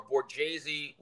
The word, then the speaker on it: Borghese: Our last names are Borghese without the H.